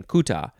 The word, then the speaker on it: Kuta: It is akin to the Bambara and Malinke word Kuta, spelled K-U-T-A. Oh, yeah, that's probably the other pronunciation, Kuta, instead of Cooter.